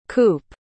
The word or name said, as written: coope